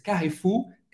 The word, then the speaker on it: Carrefour: Carrefour, em francês, Carrefour est Carrefour. Carrefour, hein, que é uma loja gigante. Aqui também, Carrefour. Você vai ter que pronunciar esse R bem francês, bem, euh, gutural, ok? Carrefour.